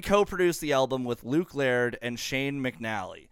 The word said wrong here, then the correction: McAnally: Or McAnally. There we go. I said McAnally.